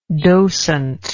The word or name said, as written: docentship